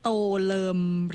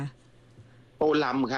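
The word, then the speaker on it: Tô Lâm: โตเลิมหรือโตลำคะโตลำ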